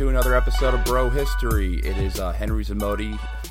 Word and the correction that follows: Zamota: Man, I just mispronounced my own name. This is not a good start. It's Henry Zamota and Daniel Abdul-Jabbar.